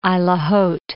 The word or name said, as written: Isle au Haut